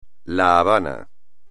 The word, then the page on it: La Habana: espagnol